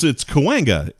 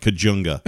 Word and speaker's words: Cahuenga: I tried to say the soft J there. It's not. It's Cahuenga, isn't it? It's like, no, it looks like Cajunga.